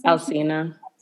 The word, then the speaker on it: Alsina: Alsina, is that how you pronounce this? – Alsina.